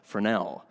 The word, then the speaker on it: Fresnel: It's French. It looks like it's pronounced Fresnel, Fresnel.